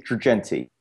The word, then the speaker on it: Girgenti: Yes, it's Dave Girgenti.